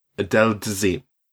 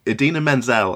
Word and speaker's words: Idina Menzel: Right, we've got John Travolta, who I primarily know from Pulp Fiction and also Adele Dazeem, which is when he butchered the pronunciation of Idina Menzel, I believe.